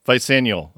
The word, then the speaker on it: Vicennial: Their most recent record is, am I pronouncing this correct? It's Vicennial. Did I say that right? Vicennial? Vicennial. All right.